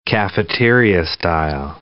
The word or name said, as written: cafeteria-style